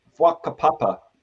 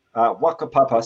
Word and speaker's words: whakapapa: the Maori concept of whakapapa, Wakapapa, spelt with a WH, Wakapapa is that word for lineage or genealogy.